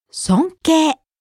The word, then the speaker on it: 尊敬: 尊敬 This is a noun meaning respect. It ends in a long A sound, like 敬。